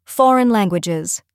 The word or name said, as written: foreign Languages